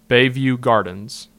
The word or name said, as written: Bayview Gardens